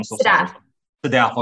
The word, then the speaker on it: Sadaf: Is it Sadaf or how do you say it? I don't want to mispronounce your name. – Sadaf.